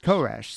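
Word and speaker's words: Koresh (כורש): And Cyrus in Hebrew is pronounced Koresh.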